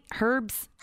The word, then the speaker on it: herbs: And am I saying it right? Herbs? – Herbs. – Herbs. – Like her. Like herbs. A lady, her.